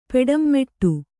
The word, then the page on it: ಪೆಡಂಮೆಟ್ಟು: Kannada